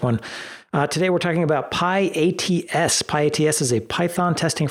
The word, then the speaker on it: pyATS: It is pyATS.